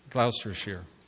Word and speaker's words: Gloucestershire: Gloucestershire.